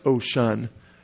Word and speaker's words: Oshun: called Osun by in their language, but usually pronounced Oshun, O-S-H-U-N, or I should say spelled O-S-H-U-N.